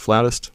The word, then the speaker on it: flautist: Your wife is a, how do you say it, flutist, flautist? – Flutist, yes. – Flutist. Is flautist like completely wrong?